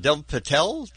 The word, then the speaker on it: Dev Patel: We have Dev Patel.